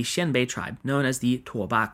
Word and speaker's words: Tuoba: I'll be going ahead and using the Mandarin pronunciation, Tuoba.